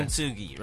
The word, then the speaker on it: Kintsugi: How do I say it? Is it Kintsugi? – Yeah. – Kintsugi, right? – Well, that's pretty interesting.